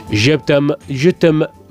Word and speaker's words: Je t'aime, je t'aime: La canción, hombre, fue, mm, fue bastante polémica para la fecha, porque el tema del, a ver si lo digo bien, Je t'aime, je t'aime, non Genial.